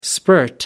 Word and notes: spurt: American English

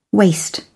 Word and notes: waste: British English

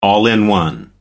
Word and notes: all in one: American English

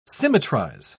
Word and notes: symmetrize: British English

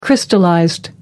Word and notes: crystallized: American English

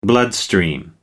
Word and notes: bloodstream: American English

